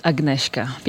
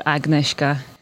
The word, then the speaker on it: Agnieszka: My name is Agnieszka. People also call me Nishka or Agnieszka.